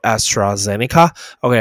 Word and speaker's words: AstraZeneca: 按你怎么发音？AstraZeneca，Astro。 Zeneca，OK，你可以说 AstraZeneca，OK，